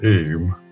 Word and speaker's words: Eyam: So this is 1666, the year after the Great Plague in London, and plague had arrived in the Derbyshire village of Eyam, is how the locals pronounce it, Eyam.